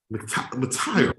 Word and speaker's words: McTyer: It turned out it wasn't McIre. McIre. McTyer. McTyer. McTyer. – So let's, get, let's get it straight. We, we've been butchering his name on this podcast for three weeks straight. Not MC Big T Y E R McTyer.